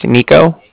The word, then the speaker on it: Niko: It's my name's spelled N-I-K-O, so that's Nico. And then the Bank of Cardiff is spelled C-A-R-D-I-F-F, as in Frank, Frank.